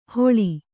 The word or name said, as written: ಹೊಳಿ